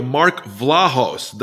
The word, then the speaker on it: Mark Vlahos: Okay, I'm on here with VT Radio, Mark Vlahos.